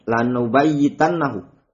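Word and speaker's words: lanubayitannahu (لَنُبَيِّتَنَّهُ): Linubayitannahu itu maknanya buat makar di malam hari. Maknanya apa? Ai liyaqtulannahu atau layaqtulannahu. Afwan itu bacanya lanubayitannahu.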